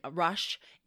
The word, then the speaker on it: Rush: But one of the things that he studied was medicine with like this famous Dr. Russ in Rush in um, Philadelphia. And Dr. Russ had Rush, I can't say his name,